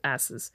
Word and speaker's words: asses: It's pronounced asses, but it is spelled asses. – Like A-S-S-E-S? – Yes, but it's asses.